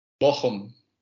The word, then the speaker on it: Bochum: Is it Bochum or is it Bochum? – Bochum. It's like Hebrew. Bochum. Difficult to pronounce.